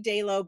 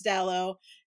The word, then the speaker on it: Dallow: People look yeah. at Dallow and they get confused because it looks like it should be Dalo, but it's Dallow.